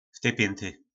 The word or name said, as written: v ty pjynty